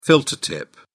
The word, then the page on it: filter tip: British English